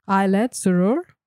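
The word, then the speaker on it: Ayelet Zurer: I have to give serious credit to, how do we say her name? Ailet Zurer? – Ailet Zurer.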